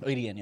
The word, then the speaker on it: Adien: A-D-I-E-N. – A-D-I-E-N. Oh, okay. – So it's spelled. – That's really weird. – People think that it's supposed to be spelled Aiden, so. – Oh, but it's pronounced ADN. – ADN, yeah. ADN.